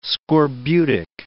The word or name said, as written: scorbutic